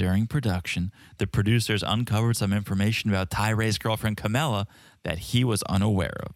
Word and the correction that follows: Carmella: It's Carmella. – Carmella. What was I saying? – Camella. – Silent R.